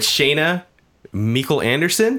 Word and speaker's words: Shayna Meikle Anderson: And we're here with Shayna Meikle Anderson.